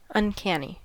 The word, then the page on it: uncanny: American English